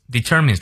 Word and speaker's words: determines: Experience 这个词音节比较多，but how we perceive perceive 常念到位，what we experience determines determines 注意它的重音，our fate。从到尾我们来过两遍。Not what we experience, but how we perceive what we experience determines our fate.